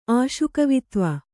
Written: ಆಶುಕವಿತ್ವ

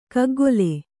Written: ಗುಗ್ಗುಲು

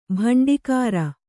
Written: ಬಂಡಿಕಾರ

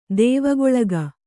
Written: ದೇವಗೊಳಗ

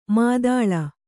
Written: ಮಧೂಳಿ

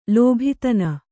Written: ಲೋಭಿತನ

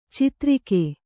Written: ಛತ್ತರಿಗೆ

